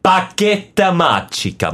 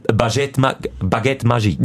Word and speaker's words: Baguette Magique: Baguette Magica. Mal es richtig ausgesprochen. – Wie heißt es auf Französisch? – Baguette Magique. – Wie geschrieben? Baguette. – Baguette. – Baguette. – Ja, es heisst einfach, es ist wirklich, es ist Baguette Magique.